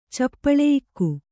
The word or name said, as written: ಚಪ್ಪಳೆಯಿಕ್ಕು